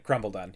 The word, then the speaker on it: Crumbledon: I think you just, if you pronounce it quickly without worrying too much about the pun, you'll get it. Crumbledon, yeah. – Crumbledon? – Like kind of like crumble down, right? Because it fits with that theme.